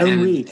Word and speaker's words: Henri: Oh, and, Henri.